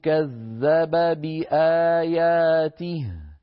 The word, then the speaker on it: كذب بآياته: كذب بي هنا التقى في النطق باءان، كذب بي آخر كذب باء، بآياته أولها باء، التقى في النطق باءان، فعلينا أن ننتبه عند التقاء المثلين حتى لا يسبق اللسان فيأتي أحد المثلين غير واضح، نقولها هكذا: أو كذب بآياته، نبين كلتا الباءين.